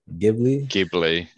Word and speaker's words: Ghibli: Coming in for number one is, I might even butcher it, Studio Ghibli. – Ghibli.